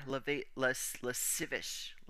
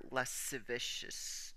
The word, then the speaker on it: lascivious: All right, let's figure out how to pronounce that word. I know it's heinous as shit, but it's LASCIVIOUS. Let's look this up so that we all sound smart. Less. And if you know how to pronounce it, I am very proud of you.